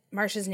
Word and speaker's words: Marcia: I said Marsha's name wrong earlier. It is James Marsha, not Marcia. So Marsha.